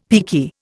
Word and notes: peaky: Indian

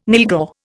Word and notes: nylghau: Indian

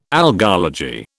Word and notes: algology: British English

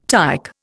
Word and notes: tike: Indian

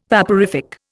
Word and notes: vaporific: Indian

